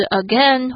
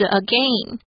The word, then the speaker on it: again: 接着呢，我们看到了 again 这个字，有人会把这边的 AI 念成短母音的 a，也有人念成长母音的 a，两种发音都是正确的哦，不管是 again 或是 again。